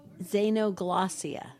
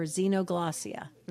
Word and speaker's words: xenoglossia: Did you know that he had the gift of it's, you could say, you can pronounce it two different ways. Let's just say it's xenoglossia or xenoglossia.